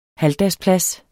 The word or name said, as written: halvdagsplads